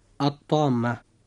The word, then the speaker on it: الطامة: كلمة الطامة جاء بعد لام التعريف في هذه الكلمة حرف الطاء، وحرف الطاء هذا من الحروف الباقية بعد الحروف 14 التي ذكرناها لحضراتكم، فننطق اللام هنا بطريق الإدغام فنقول الطامة.